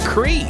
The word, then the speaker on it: Cri: is Your Unity with Contagious and Jem Lang, Fresh 92.7, and that one coming out last year from Cry, that was Stranger. – Yes, you said Cry, I've been saying Cry, but apparently it's pronounced Cree because he's French-Canadian, so it's like Cree.